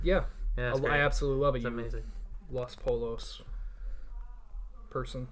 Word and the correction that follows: Los Pollos: Los Pollos? – Yeah. – Two L's is pronounced as a yeah.